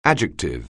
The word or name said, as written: adjective